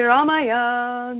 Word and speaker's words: Ramaya: When the mantra is chanted, in my lineage, there is an inflection on the second syllable of Ramaya. So when it's sung, it sounds like this. Om Shri Ramaya, Namaha. Om Shri Ramaya, Namaha.